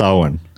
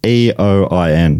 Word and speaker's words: Eoin: It's not Ian, is it? It's Owen. – I don't know myself. – Eoin. – Yeah, that's usually Owen.